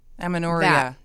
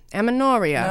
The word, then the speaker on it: amenorrhea: Aminoria. – Aminoria.